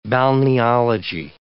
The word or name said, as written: balneology